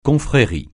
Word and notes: confrérie: francais

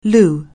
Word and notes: loo: anglais

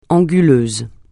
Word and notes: anguleux: francais

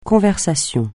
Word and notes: conversation: francais

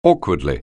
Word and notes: awkwardly: anglais

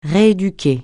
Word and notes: rééduquer: francais

